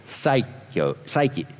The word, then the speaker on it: psyche: The psychiatrist, the first part of the word, is psyche. I can't even say it. It's Psyche.